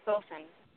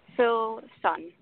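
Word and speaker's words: Silsan: Sulson. Sulson. – Spell it for me, please. – S as in Friday, I as in igloo, L as in Larry, S like Sunday, A like animal, N like Nancy.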